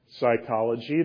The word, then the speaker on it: psychology: Psychology, the P is silent.